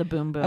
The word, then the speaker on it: Bum Bum: It is called the Boom Boom. – Okay. It's spelled Bum Bum. Yeah, so that's confusing. But, boom, boom.